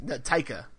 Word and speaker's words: Taika: Yeah, Taika. – Taika Waititi. I never can get Taika.